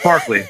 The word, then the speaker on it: Barclay: They call him Barkley. That's a joke. – Yeah. – It's Barkley. – When they're all bullying Barkley, and you're like, Jesus, guys, what's going on?